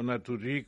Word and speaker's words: Naturgy: Sí, Naturgy, o Naturgy, como se quiera pronunciar, pues, Naturgy.